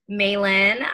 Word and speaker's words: Mailyn: My name is Maylin. My Instagram and all of my social media is linked at That So Maylin, M-A-I-L-Y-N.